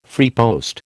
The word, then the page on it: freepost: American English